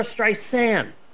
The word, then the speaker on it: Streisand: Apparently, it's not Barbara Streisand. There's no D. It's Barbara Streisand. And I've just been mispronouncing Barbara Streisand's name my entire life. Barbara Streisand.